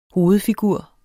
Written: hovedfigur

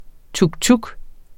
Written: tuktuk

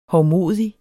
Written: hovmodig

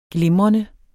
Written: glimrende

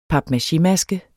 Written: papmachémaske

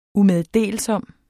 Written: umeddelsom

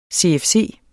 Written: CFC

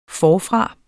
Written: forfra

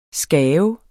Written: skabe

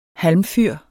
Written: halmfyr

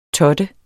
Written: TOTTEDE

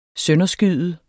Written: sønderskyde

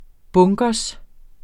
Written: bunker

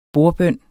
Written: bordbøn

